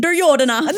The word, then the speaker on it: Duryodhana: I can say it with an Indian accent. Duryodhana. – There you go.